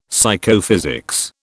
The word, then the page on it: Psychophysics: British English